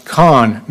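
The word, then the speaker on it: Kahan: To be well-versed in canology, you should know that his name is pronounced Khan, not K-A-H-A-N. I just say one of the A's in the name is silent, Khan says. You can choose which one.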